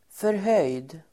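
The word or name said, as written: förhöjd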